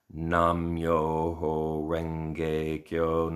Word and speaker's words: Nam Myoho Renge Kyo: The main chant that we have is basically pronouncing the title of the Lotus Sutra in using its Chinese characters, but pronounced in a Japanese way. So, Nam Myoho Renge Kyo, Nam Myoho Renge Kyo.